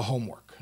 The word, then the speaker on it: homework: So how about a little homework? No pun intended. Or, yeah, maybe why not? Pun intended. Little homework. question is, is the emphasis on the, the word home or the emphasis on the word work?